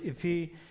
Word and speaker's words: epi: But in the Greek, epi, E-P-I is how it's spelled. The accent is on the I.